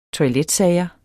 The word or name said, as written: TOILETSAGER